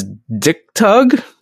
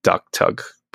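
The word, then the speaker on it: d'k tahg: That the name of that blade is spelled D'KTAHG, which makes it sound like you could read it as Dick Tug. – Give him the old Dick Tug. – He has to pull on that blade to draw some of that hot sauce. – Give him a little Dick Tug. – Maybe that's why it's called that. – Okay, and I'll head off the pedants right now. I know it's actually supposed to be pronounced like Duck Tug, but it's just funny.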